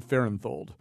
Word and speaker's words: Fahrenthold: It's David Fahrenthold.